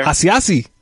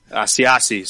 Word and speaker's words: Asiasi: Asiasi. – Asiasi.